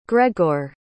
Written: gregor

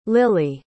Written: lilie

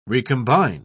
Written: recombine

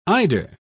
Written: eider

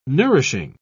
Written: nourishing

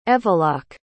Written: evalach

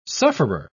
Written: sufferer

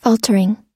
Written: faltering

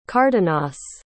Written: cardenas

